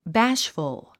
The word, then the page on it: bashful: bǽʃfəl; バッシュフゥ